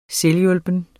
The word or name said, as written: selvhjulpen